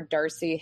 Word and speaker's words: Darsie: Darcy Historian. And in case you don't remember, my last name is spelled D-A-R-S-I-E.